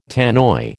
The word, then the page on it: Tannoy: American English